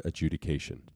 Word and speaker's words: adjudication: Adjudification. Aren't you missing a couple letters there? – Deferred adjudication. – Adjudification. – There's no F in adjudication.